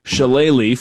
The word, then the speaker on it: shillelagh: I think I could practice spelling shillelagh for the next decade, and I wouldn't get it right. But am I at least pronouncing it correctly? – You're pronouncing it perfectly.